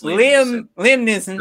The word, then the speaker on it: Neeson: Neeson. It's Neeson. Yeah. There's no L.